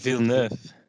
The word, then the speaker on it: Villeneuve: De Niet Ja, de Neneville dan? Of was het... – Villeneuve. Villeneuve. Ja.